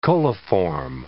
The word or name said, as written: coliform